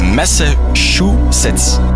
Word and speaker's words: Massachusetts: Het is een hit over de Amerikaanse staat Massachusetts. Ja, ik ben niet goed in dat te uitspreken, ik heb al vaak fout gezegd, maar het is Massachusetts.